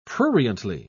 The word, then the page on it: pruriently: British English